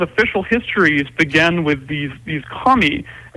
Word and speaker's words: kami: And, I get one of the things you deal with a lot is the concept, I don't know if I'm saying it right, but the kami? – K-A-M-I? Yeah. – And what are K-A-M-I. – These? – K-A-M-I, yeah. – How was it said? – Kami, that's right.